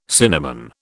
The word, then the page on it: cinnamon: British English